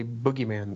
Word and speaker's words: boogeyman: I always wondered why Americans say boogeyman, though. Here in the UK, people always say bogeyman.